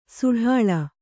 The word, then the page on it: ಸಡಾಳ: kannada